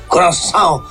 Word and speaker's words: Curaçao: I nostri amici del Curaçao, come si dice bene, Curaçao, la pronuncia, ne hanno presi 7.